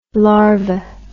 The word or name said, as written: larvi